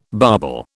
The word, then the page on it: bobble: British English